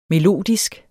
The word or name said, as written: melodisk